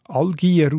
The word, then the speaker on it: Algier: Das wird allerdings als Algier ausgesprochen, respektive Berndeutsch ins Algier uche.